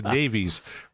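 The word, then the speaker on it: navvy: No, Navi. Like a flat A. Navi. Because where it comes from is navigational engineers.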